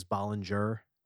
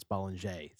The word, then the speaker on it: Bollinger: And first, I'm even going to start with the pronunciation, because when you see the bottle, everyone says Bollinger, because that's how it's spelled. But it's French, so it's Bollinger.